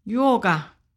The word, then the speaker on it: Yorga: Yorga.